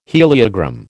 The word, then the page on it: heliogram: American English